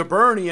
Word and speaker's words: Burney: Now, we were calling it Bourne, but we met a local that escaped Skankafornia and moved to Burney, and he called it Burney. So, I think that's the correct pronunciation.